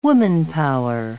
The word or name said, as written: womanpower